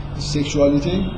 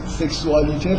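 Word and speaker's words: sexualité: سکشوالیته واژه صد درصد تلفظ غلطی برای اینکه اگه سکسوالیته فرانسه است سکشوالیتی انگلیسیه من یه جوری این تا با هم دیگه قاطی میکنم و واژه‌ای که وجود نداره رو تلفظ میکنم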